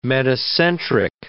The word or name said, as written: metacentric